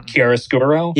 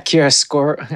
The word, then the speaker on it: chiaroscuro: Like Chiarosco? Is that, I don't know. – Chiaroscuro? – Yeah, Chiaroscuro. I can't ever pronounce it.